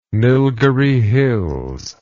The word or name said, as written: Nilgiri Hills